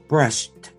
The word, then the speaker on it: brushed: You know, I know there's an ED at the end of that word, but listen to the pronunciation, brushed, right? – Yeah, it's a soft D like a T sound. – It is not brushed. It is not. – That's brushed. Right.